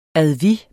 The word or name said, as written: advis